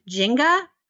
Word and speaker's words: Nzinga: um, and one of the pronunciations for her name is Jenga, mm-hmm. – and I kept thinking of Jenga, the game.